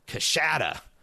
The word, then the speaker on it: Coushatta: also grew up near Shreveport and says nearby town of Coushatta, pronounced Coushatta.